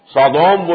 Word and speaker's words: سودوم: سودوم وہ شہر تھا، سدوم جس کو ہم کہتے ہیں، سودوم، S O D O M, یہ بائبل میں اس کا تلفظ ہے، تو اس سے یہ سودومی کا لفظ بنا ہے.